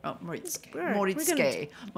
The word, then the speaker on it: Moritsuké: This talk on Moritsuké. – Yes. – Missed a syllable. Moritsuké.